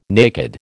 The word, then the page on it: naked: American English